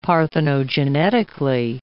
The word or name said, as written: parthenogenetically